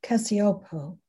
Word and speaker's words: Cacioppo: Dr. Sepio, is that his name? Out of Chicago, who wrote the book Loneliness. – Cacioppo. – Okay. Well, Cacioppo, I know he's passed away, and he wrote a phenomenal book called Loneliness.